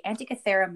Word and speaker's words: Antikythera: So what I want to talk about today is called the Antikythera mechanism. It's called the Antikythera mechanism. It's Greek. – Antikythera mechanism. – Right. So it's spelled A-N-T-I-K-Y-T-H-E-R-A. Antikythera mechanism.